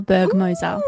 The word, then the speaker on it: Bergmoser: My first book is called The Hunted by Gabriel Bergmoser. Now, I know that you know Gabe. Am I pronouncing his name correctly? – Yes, I believe you are.